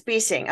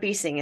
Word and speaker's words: Besing: It's Besing. Okay. Yes. I was like, is it Besing or Besing? And I'll answer to both. – Perfect. But Besing is the correct pronunciation.